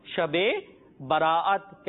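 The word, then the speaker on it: شب براءت: ہاں، تو مدنی منو شب برات نہیں ہوتا، ہم آپ کا تلفظ درست کرا دیتے ہیں، اس کا پروننسیشن درست کرا دیتے ہیں. اصل لفظ ہے شب برات.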